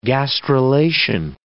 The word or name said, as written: gastrulation